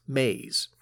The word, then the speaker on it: Mays: The main character, you should know, her last name is spelled M A Y S, Maze.